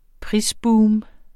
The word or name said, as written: prisboom